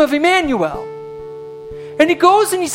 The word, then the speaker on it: Emmanuel: It rhymes with Samuel. What is that name? It begins with I-M. Emmanuel!